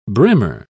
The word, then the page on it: brimmer: British English